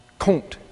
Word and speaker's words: Comte: Augustine Comte is his name, spelled C-O-M-T-E. However, Webster's Biological Dictionary tells us you pronounce that Comte.